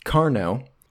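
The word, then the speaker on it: Qarnot: Carno. It's spelt with a Q, but it's Carno.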